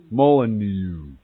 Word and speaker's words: Molyneux: Moulinex. It wouldn't be X. He's French or something. He's a bald guy. He does videos. Molyneux.